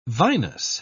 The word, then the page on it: vinous: British English